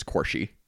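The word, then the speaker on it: Quarshie: And I believe that the actor pronounces his last name as Quarshy.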